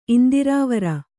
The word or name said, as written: ಇಂದಿರಾವರ